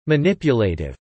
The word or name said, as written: manipulative